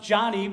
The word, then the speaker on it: Joni: Her name is actually pronounced Johnny because her dad wanted a boy and ended up with a girl.